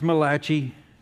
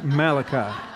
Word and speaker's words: Malachi: It's not pronounced Malachi. It's pronounced Malachi. Just ask anybody named Malachi. They'll let you know.